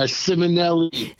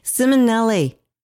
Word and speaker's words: Simonelli: He is, did I say it right, Anthony? Uh, Simonelli. Simonelli.